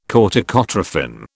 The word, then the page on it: corticotrophin: British English